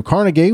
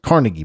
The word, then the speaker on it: Carnegie: In 1835, Andrew Carnegie was born. I used to pronounce it Carnegie, but actually I think it's pronounced Carnegie.